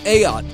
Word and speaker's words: Ayotte: Ayotte is how you say the name. I had to go to the website that gives you pronunciations for some of these names of public figures.